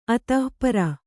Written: ಅಥ

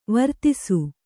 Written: ವಿರಾಧಿಸು

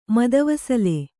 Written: ಮದವಸಲೆ

